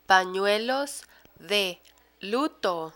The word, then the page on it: panuelos de luto: Spanish